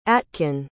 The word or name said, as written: atkin